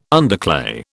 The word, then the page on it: underclay: British English